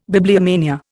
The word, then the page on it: bibliomania: Indian